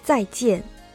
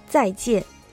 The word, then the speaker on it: 再见: 発音が再见、再见。